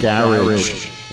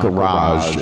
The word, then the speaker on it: garage: And also for further study, check out the compilation that came out back in the early 2000s called Gorilla Garage, which is how they would pronounce it in the UK. It's not garage, it's garage.